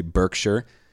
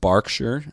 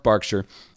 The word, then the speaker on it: Berkshire: It is spelled how Americans would say Berkshire, but it's actually pronounced Berkshire.